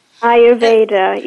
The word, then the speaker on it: Ayurveda: Ayurveda, correct? – Ayurveda, yeah.